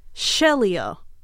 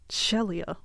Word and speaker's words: Shelia: The main character's name isn't Sheila, even though it should be pronounced Sheila. It's spelt Sheila. It's Shelia. And I don't like it. – Me either. – Just Shelia.